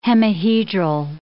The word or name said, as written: hemihedral